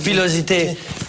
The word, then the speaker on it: pilosité: Pilosity. – Non, en français, Eric, regarde. Pilosité. P-I-L-O-S-I-T-I. Pilosité. – Très bien prononcé.